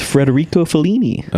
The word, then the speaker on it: Federico Fellini: Wait, say his name again? – It's Federico Fellini.